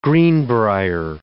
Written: greenbrier

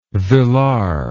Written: villard

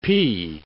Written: pee

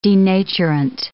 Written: denaturant

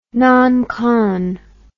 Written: noncon

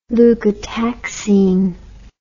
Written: leukotaxine

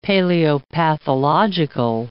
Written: paleopathological

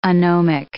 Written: anomic

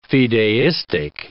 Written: fideistic

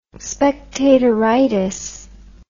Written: spectatoritis

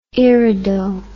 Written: irido

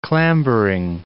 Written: clambering

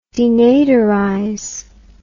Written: denaderize

